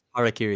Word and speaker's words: Harakiri: Yes, Harakiri.